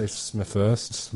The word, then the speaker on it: Smethurst: Am I saying this right? Smethurst? – The News Corp one? – She is... I think she's News Corp, yeah. And then you've got broadcaster Ben Fordham. And he was a broadcaster from 2GB. Okay, so this... How do you say this woman's bloody name? Smethurst? – I'm going to say Smethurst.